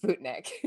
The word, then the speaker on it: Sputnik: But not how it's pronounced. It is pronounced Sputnik.